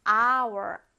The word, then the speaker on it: hour: 자, 생긴 거는 자음처럼 생겼지만 어떻게 발음할까요? hour, 아 발음이죠. 그래서 an hour.